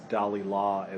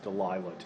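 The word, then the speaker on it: Dalila: He tells Dalilah, as we pronounce her name in Samson Agonistes, Delilah to you.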